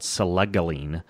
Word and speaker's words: selegiline: The World Anti-Doping Association does list modafinil, adrafinil, phenylparacetam, and one called selegiline.